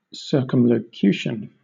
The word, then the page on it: circumlocution: British English